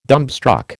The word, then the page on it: dumbstruck: American English